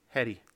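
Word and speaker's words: Hedē: It's H. Well, how would you pronounce H E D E? – A what? – Heady. – H E D E. – Yeah, that's how they're saying to pronounce it. – That would be Heed. – That's Heed. – Look, actually, Heed H E E D. – H E D E with a line over the second E. – Oh. – Yeah. Yeah. Okay. So the pronunciation is Heady.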